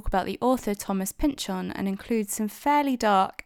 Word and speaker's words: Pynchon: I'm saying Pynchon because that is apparently how he pronounces his name. We know this from a Simpsons episode that he appeared on once.